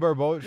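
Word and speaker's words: Barbeau: I was going to say, Adrian, I always butcher her last name, Barboa. Is that how you say it? Yeah, Adrian Barboa.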